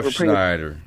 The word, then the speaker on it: Refsnyder: Yeah, Refsnyder.